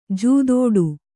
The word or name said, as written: ಜೂದಾಡಿ